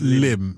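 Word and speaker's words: Lympne: Spelt Limpney, but it's pronounced Lim.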